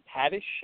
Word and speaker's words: Haddish: Speaking of night school, did either of you guys see that with Kevin Hart and Tiffany Haddish? I think that's how her last name is pronounced. – No. – Oh, yeah, that is who you're talking about. You said the name right, but no, I did not see that.